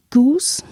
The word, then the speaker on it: Geuss: Und eine letzte schöne und kurze Zusammenfassung zu den fließenden Grenzen zwischen Öffentlichkeit und Privatheit ist von Raymond Goose, nämlich spricht man ihn aus, Geuss geschrieben, Privatheit, eine Genealogie.